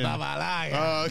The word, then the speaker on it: Arrizabalaga: Big moment of the game was Kepa Arrizabalaga. – Oh my God, bro, the pronunciation. – Arrizabalaga. – Okay, that was clean. – You might see white cheeks at the beginning. – That was clean. – That was clean.